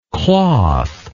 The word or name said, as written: clothlike